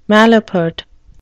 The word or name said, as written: malapert